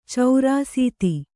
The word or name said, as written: ಚೌರಾಸೀತಿ